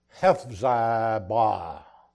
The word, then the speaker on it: Hephzibah: "But thou shalt be called Hephzibah, that's how that's pronounced.